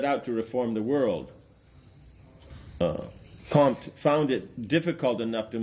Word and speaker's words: Comte: Comte. – Do you say the M? – Yes. – Comte.